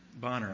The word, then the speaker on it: Bonar: Andrew Benar, Bonner, I think is how you pronounce his name, B-O-N-A-R, Bonner.